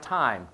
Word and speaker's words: time: And it's spelled like time, T-I-M-E, pronounced time.